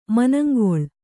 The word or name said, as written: ಮನಂಗಲ್